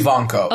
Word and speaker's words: Evancho: But when we learned who it was, and you're going to have to help me pronounce her last name. It's Jackie Evancho. How do you Ivanko. – Ivanko? Ivanko. Yeah. Okay, that's why I kept getting confused.